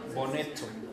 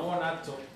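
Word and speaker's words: boneco: Ajá, bonetto. No bonato, bonetto.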